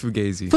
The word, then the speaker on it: Fugazee: I say it like Fugazi. Is that wrong again? – It's Fugazi. – Fugazi. Fugazi. Oh, like the gaze.